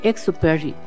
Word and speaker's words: Antoine de Saint-Exupéry: Entonces, pero dice aquí, ¿cómo se pronuncia? Antoine de Saint-Exupéry. O sea, la acento Antoine, en la E. De San. – De San. – Ej. – Ex. – No, eje. Exupéry. – Exupéry.